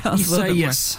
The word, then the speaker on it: Isaias: Isaias. – Isaias. – Yes, which is not intuitive at all. I had to check the official National Hurricane Center pronunciation guide for that one because tough one.